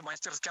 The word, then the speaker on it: мастерски: требовал не только знаний своей профессии, но и вот именно что вот этих вот нюансов, которыми они, в общем-то, мастерски обладали, конечно. – Мастерски. Такое ударение стало нормативным всего лишь год назад. А раньше правильным вариантом считалось только мастерски.